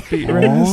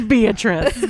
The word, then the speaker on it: Beatrice: Beet rice. – It's like, first of all, I get that it's spelt beet rice. Nobody's name is beet rice. Her name is Beatrice.